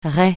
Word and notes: rai: francais

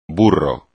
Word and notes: burro: espagnol

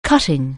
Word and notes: cut: anglais

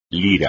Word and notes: lira: Spanish

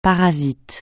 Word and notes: parasite: francais

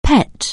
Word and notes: pet: anglais

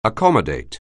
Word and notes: accommodate: anglais

